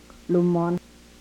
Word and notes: lumon: Yurok

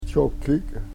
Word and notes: chokik: Yurok

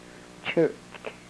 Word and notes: chrk: yurok

